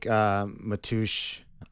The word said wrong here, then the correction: Mateusz: I'm saying that right, right? Matush? – I think it's Mateusz.